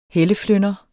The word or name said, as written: helleflynder